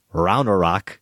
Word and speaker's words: Ragnarok: The most recent entry in the MCU is Thor, Ragnarok, correct pronunciation learned from y'all's podcast.